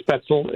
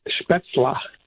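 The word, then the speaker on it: Spätzle: It's not special. It's not Spetzel. It's Spetzla.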